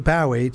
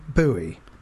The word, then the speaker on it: Bowie: I actually saw it says here anyone who pronounces it Bowie to rhyme with Howie or Towie is wrong.